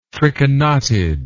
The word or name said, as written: trichonotid